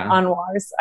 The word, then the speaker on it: Anwar: Did I pronounce that right? – Anwar's.